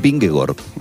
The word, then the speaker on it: Vingegaard: a decir el nombre real de Erin Halland, que es Hallon. La doble A en los idiomas escandinavos se pronuncia como una O. Es decir, el ciclista danés Vingegaard es Vingegaard, por raro que suene.